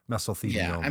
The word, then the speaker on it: mesothelioma: But measles fasciloma is still a problem, and you can still order a free book. I can't. I've seen that for 25 years, and I still can't pronounce the disease. Mesothelioma.